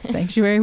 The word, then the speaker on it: psanctuary: It's pronounced sanctuary, not psanctuary. – Sanctuary with a P.